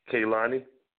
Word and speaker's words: Kehlani: Kehlani? – Yes.